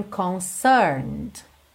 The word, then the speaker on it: concerned: Atenção aí que o E o último é de concerned, não é pronunciado, certo? Então você vai do N para o D. Concerned. Concerned. Uhum.